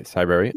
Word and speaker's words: Cybrary: Cybrary, is that? – Right, Cybrary.